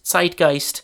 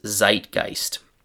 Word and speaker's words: Zeitgeist: German pronunciation is Zeitgeist.